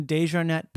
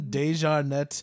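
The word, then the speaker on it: deJarnette: It's my full name is John Desjarnet Pemberton III.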